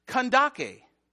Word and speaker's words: Kandake: Now, this verse has always confused me because in other translations, Kandake is spelled C-A-N-D-A-C-E, which any Full House fan knows, is pronounced Candace, right? But this is, it's actually, even though it's spelled that way, it's pronounced Kandake.